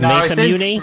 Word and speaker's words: Masamune: And in the Mass Immune Chirao video game, horse will... – I'm sorry, I need you to repronounce that immediately. – I think it's Masamune. – Masamune.